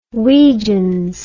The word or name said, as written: weejuns